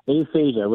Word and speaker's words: Aphasia: Aphasia, which literally means can't talk. Okay. And it's a little frustrating for me when I read the papers, the tabloids, et cetera. It just says he has aphasia, but it doesn't really give me a reason why he's not able to talk.